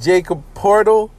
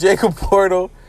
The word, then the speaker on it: Poeltl: His last name starts with a P, and it's spelled crazy. I believe it's Jacob Poeltl, even though there's like mad L's, an E, and a T in there.